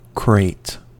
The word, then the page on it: crate: American English